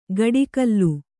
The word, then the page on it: ಗಡಿಕಲ್ಲು: gaḍi kallu